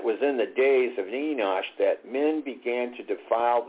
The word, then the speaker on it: Enosh: That's E-N-O-S-H, Enosh. Now, Seth's son was called Enosh, and actually this is pronounced Enosh, and that word actually means mortal.